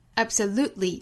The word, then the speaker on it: absolutely: 还有像 absolutely， absolutely，这里面的 t 的音也可以略掉。